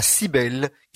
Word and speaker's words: Cybèle: Et les Romains consacraient le mois d'avril à Cybèle, qui s'écrit C-Y-B-E-L-E, e, enfin E accent.